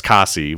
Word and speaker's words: Käsi: where he butchered the name of Kasi, which is how it's pronounced uh, from the the old.